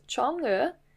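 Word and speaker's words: Chang'e: Okay, so her name, with tones, is pronounced Chong'e. This consists of two characters with rising tones.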